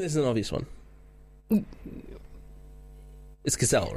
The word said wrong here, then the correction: Gisele: right? – I think it has to be. Yeah. I think it has to be. – Am I pronouncing that right? – I think it's Gisele Pellico.